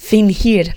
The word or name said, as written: fingir